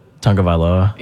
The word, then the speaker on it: Tagovailoa: Tagovailoa? – Yeah.